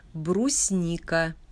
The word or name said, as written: Brusnika